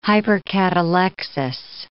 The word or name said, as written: hypercatalexis